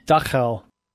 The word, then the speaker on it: Dachau: A Dachau, is that how it's pronounced? – Dachau. – Dachau.